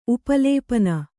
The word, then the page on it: ಉಪಲೇಪನ: Kannada